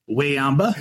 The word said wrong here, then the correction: Wembanyama: Very close. – Wayamba? – I believe it's Wembanyama. Wembanyama.